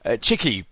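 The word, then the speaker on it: Txiki: his name Tixi Begistaran, I think you pronounce it or something like that, yeah, okay. – Cheeky Begistaran. – Cheeky. – Thank you very much.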